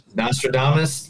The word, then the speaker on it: Nostradamus: As of Friday, I have become known as the Nostradamus. Is mm-hmm. that how you say it? – Nostradamus of pro wrestling when I predicted the end of Vince McMahon.